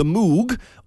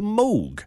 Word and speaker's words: Moog: I want to talk about what's commonly known as the Moog, or as it should be pronounced, the Moog.